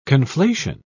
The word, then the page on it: conflation: British English